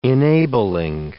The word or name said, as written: enabling